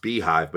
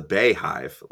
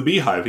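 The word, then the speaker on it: BeyHive: He said the beehive, pronounced beehive, but I've always pronounced this the Bayhive, like for Beyonce. – No, it's the beehive. He's right.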